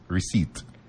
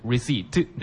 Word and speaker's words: receipt: Yeah, the P is silent, so it's receipt。 – P is silent とおっしゃいましたね。 – そうですね。発音しない音です。 P ですね。 – はい。 P は silent なので、皆さん発音しないので、 receipt ですね。